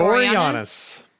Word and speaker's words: Boreanaz: I don't know how to say his last name. David Boreanaz or whatever. – Boreanaz. – Yeah, there you go.